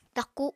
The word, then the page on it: taku: apatani